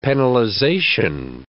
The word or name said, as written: penalization